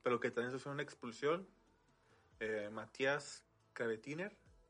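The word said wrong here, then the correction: Kranevitter: no sé cómo se pronuncia. – Craneviter.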